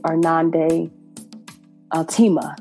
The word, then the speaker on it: Irnande Altema: So I am incredibly honored to be here with the Irnande Altema.